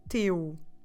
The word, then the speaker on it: teu: And your son, talking to a friend, have this in mind, is the possessive teu. Teu. Como se escreve teu? T-E-U. Teu. Quite similar to meu, if you remember.